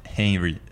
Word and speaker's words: Henry: Só, só uma questão que eu falei errado, o nome é Henry.